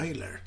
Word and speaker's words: Tyler: Steven Taylor. – Mm. – Tyler, hur uttalar han? – Taylor. Tyler. Tyler. – Han heter väl Tyler?